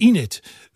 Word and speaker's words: Enid: Da haben wir immer die Bücher von Enid Blyton gekannt, aber es ist natürlich Enid, so wird der Name ausgesprochen.